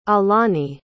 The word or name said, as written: aulani